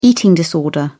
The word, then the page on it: eating disorder: British English